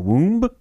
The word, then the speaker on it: womb: You say it womb like W O O M. Yeah, B womb. is silent. That's um, such a you, weird word. Yeah, you wouldn't throw a hard B on the end of that.